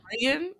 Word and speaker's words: Rhian: So there were two twins, Rian, is that how you pronounce that? R-H-I-A-N. – That's Rian? What I would assume. Yeah, Rian.